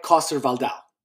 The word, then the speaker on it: Coster-Waldau: Nikolai Coster-Waldau.